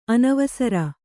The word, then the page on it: ಅನವಸರ: anavasara